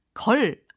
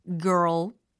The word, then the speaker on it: girl: 특히 이 girl 발음 되세요? R과 L 발음 이거 합치니까 earl, earl 요 사운드가 나야 됩니다. 그래서 걸 아니고 girl, girl 그렇죠.